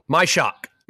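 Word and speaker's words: Majchrzak: Majerzak. – Nobody pronounces the name well. – My shock. – My shock. – Majerzak. – Doesn't sound right. – It's literally just my shock.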